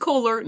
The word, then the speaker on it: Kolar: Also, if you just go to DM's Guild and search for Pride Lands or Tom Kohler, then you'll be able to find it. – Tom Kohler is K-O-L-A-R, like polar or solar, but with a K. It's not like the faucet.